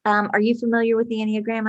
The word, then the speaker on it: Enneagram: Enneagram, yeah.